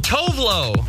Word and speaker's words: Tove Lo: Okay, T-O-V-E-L-O. – Oh, Tovlo.